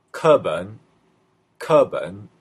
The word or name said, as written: keben